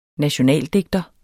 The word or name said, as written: nationaldigter